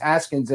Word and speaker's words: Haskins: Haskins and the offense of the Washington football team. – You said it right. I'm proud of you. You said it right. You got it right.